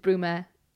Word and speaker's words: Bruma: So she would spell it like B-R-O-M instead of B-R-U-M. So my sign name is Mrs. Bruma, like somebody sweeping with a broom.